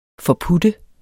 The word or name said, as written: forputte